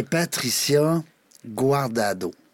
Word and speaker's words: Patricia Guardado: Aujourd'hui, on a, ben, un beau nom, toi aussi, hey, Patricia Guardado.